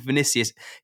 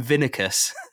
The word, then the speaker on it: Vinicius: I'm like, how have you interpreted Leno is Lino, and he called Vinicius, he calls him Vinicus.